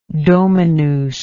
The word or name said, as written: dominus